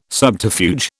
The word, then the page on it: subterfuge: British English